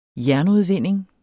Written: jernudvinding

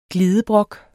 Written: glidebrok

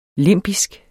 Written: limbisk

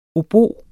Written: obo

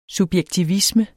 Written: subjektivisme

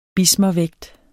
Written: bismervægt

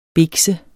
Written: bikse

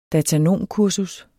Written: datanomkursus

